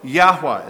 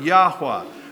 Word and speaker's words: Yahweh: Yahweh. That's his personal name. That's how you pronounce the Hebrew. You must serve Yahweh, the only true and living God.